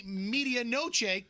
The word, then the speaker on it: medianoche: I had the media noche Cubano. And he ate mine. – Yeah. – No, you have mispronounced like 10 words in the past 10 minutes. – It's medianoche Cubano sandwich.